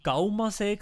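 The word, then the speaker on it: Caumasee: Jetzt gerade ist er im äh, in Graubünden, er war wieder am Gaumasee gewesen. Ich hoffe, das spricht man so aus. Ja.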